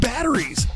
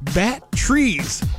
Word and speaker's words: batteries: Deborah's going to start and she says, the word batteries. My ex-boss pronounced it like bat trees.